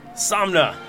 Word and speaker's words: Somna: Is that how you pronounce it? – Somna. – Somna. Somna.